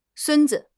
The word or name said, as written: sun1 zi5